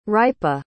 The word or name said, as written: hrypa